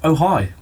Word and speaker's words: Ojai: And it's pronounced Ojai.